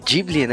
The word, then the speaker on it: Ghibli: Ela também manda como sugestão serviço de entregas que, que eu gosto pra caramba desse filme do estúdio estúdio Ghibli, Ghibli, né? Que é a pronúncia verdadeira.